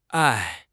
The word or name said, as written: ai4